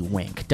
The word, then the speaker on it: Winc: But actually it's spelled W-I-N-C, Wink, W-I-N-C.